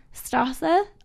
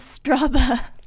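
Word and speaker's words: Straße: Jake, that's not a B. That's one of their S's that look like B's. The double S. – Oh, the double S. Yeah. – Sneaky. Okay. My friend Lauren was pronouncing Strasse, all of the streets, as Straba when she is there.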